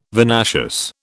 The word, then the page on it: vinaceous: British English